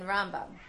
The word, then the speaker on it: Rambam: But Ramban, with the stress on the second syllable, actually moved to Israel. And in Israel, the way people speak Hebrew there, the accent is more likely to be put on the second syllable, as opposed to Rambam, who lived his life in Galut, where the stress is on the first syllable.